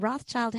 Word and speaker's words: Rothschild: And Rothschild has this sneaky silent S in it. So it's Rothschild.